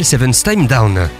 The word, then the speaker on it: Seventh Time Down: Il s'appelle Seven Time Down. Oui, ça s'écrit avec un 7 pour commencer, mais ça se prononce bien comme ça.